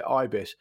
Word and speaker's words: ibis: I believe this is a reference to the Ibis bird, and I believe you're correct to pronounce it Ibis.